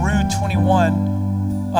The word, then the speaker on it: Rue 21: Uh, uh, so they're in the they're in the mall and right in Rue 21.